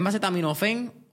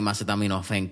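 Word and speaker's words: acetaminofén: ¿Es macetaminofen o macetaminofen? – con acento en la de macetaminofen. Fen, ok, exacto, por eso.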